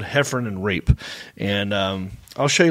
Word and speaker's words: Heffron: Some people mispronounce it and say Heffron. It's actually Heffron.